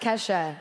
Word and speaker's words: Kesha: Our top-of-the-line models are all working with Adele, with Taylor, Miley Cyrus, key dollar sign, ha. – I think... I think that that's pronounced Kesha.